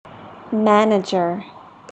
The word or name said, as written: manager